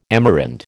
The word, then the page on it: Amerind: American English